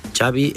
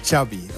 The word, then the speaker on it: Xavi: Xavi Hernández. – Xavi. – Yeah. So I heard it. Xavi like chocolate.